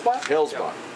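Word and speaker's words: Hellesbock: Hellsbock. – Okay, so that second E is silent pretty much.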